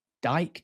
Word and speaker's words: Dicke: I think his surname's actually Dyke, D-I-C-K-E, it's pronounced.